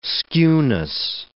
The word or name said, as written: skewness